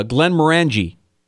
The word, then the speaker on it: Glenmorangie: uh, Glenmorangie.